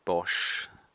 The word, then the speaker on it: Bosch: There are three levers that Haber and Bosch, that's how you pronounce it.